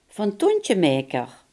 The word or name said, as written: druktemaker